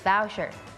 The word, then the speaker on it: voucher: มันจะออกเป็น vow vow อย่าลืมตัว v ด้วยนะคะอย่าลืมตัว gift gift voucher